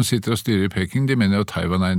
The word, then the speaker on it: Beijing: Uh, de, I Peking så vil de Jeg sier Peking også, det er gammel vane. – Beijing. – Ja. Men det er så gammel Peking. Det var den gamle uttalen.